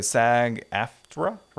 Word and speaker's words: SAG-AFTRA: So SAG-AFTRA, right? Is mm-hmm. that how you pronounce it? Yeah, so they pretty much voted.